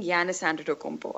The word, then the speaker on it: Giannis Antetokounmpo: And now I've started really liking Giannis Antetokounmpo.